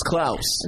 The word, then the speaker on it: Klaus: It's Klaus.